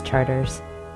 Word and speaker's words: Chartres: Chartres is Charters.